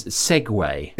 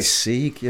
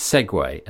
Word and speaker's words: Segway: So a bit of swift research reveals that it is Segway. – Is it? I always say Sieg, yeah.